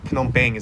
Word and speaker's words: Phnom Penh: I'd say the best starting point for an entry-level Cambodian situation is Phnom Penh Noodle House, or Phnom Penh is how you pronounce it.